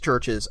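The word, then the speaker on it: CHVRCHES: I think you're thinking of Churches. – it's pronounced Churches. I'm almost certain.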